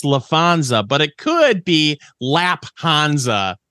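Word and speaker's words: Laphonza: So he went ahead and appointed Lafonza. – It's spelled with a PH, like fish. – Yeah. – The band, not the animal. So it's. – Are we sure it's not Lapanza? – We're not sure is the thing.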